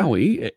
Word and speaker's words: Maui: And is it Maui? Is that how you pronounce it? Or am I pronouncing it? – That's right. Yes. So just like the island, right? Okay. Maui, M-A-U-I, right?